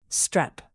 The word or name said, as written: strep